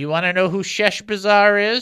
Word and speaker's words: Sheshbazzar: Let me say it right. S-H-E-S-H. Sheshbazzar. Sheshbazzar. That's it. Sheshbazzar. Almost like Chef Bazaar, but Sheshbazzar.